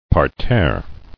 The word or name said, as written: Parterre